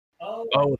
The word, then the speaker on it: Momoa: Wait, his last name's Momo? – I don't know what his last name is, how to pronounce it. The guy that played Aquaman. – Momoa. – Okay. Momoa, yes, sorry.